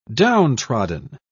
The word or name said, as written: downtrodden